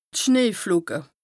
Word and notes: d' Schneeflocke: Bas-Rhin; Strasbourg

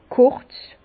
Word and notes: kùrz: Bas-Rhin; Herrlisheim

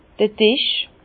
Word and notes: d'r Disch: Bas-Rhin; Herrlisheim

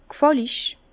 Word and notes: g'fàllig: Bas-Rhin; Herrlisheim

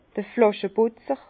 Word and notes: d'r Flàscheputzer: Bas-Rhin; Herrlisheim

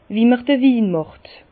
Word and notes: Wie m'r d'r Win màcht: Bas Rhin; Herrlisheim

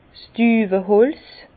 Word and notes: 's Düweholz: Bas-Rhin; Herrlisheim

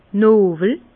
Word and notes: nowel: Bas-Rhin; Herrlisheim

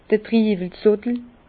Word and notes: d'r Triiwel Zottel: Bas Rhin; Herrlisheim

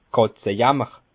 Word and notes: Kàtzejàmmer: Bas Rhin; Schiltigheim

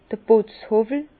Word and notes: d'r Putzhowwel: Bas-Rhin; Herrlisheim

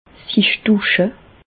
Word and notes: sich dusche: Bas Rhin; Reichshoffen

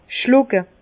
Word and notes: schlucke: Bas-Rhin; Herrlisheim

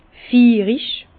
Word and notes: fiirig: Bas-Rhin; Herrlisheim

